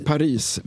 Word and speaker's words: Paris: Vi säger Paris med S, inte utan S.